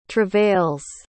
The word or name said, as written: travails